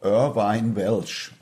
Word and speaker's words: Irvine Welsh: Ja einen schottischen Autor, Irvine Welsh, auch perfekt. Das war ganz gut ausgesprochen.